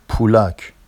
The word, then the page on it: pulɑc: Vafsi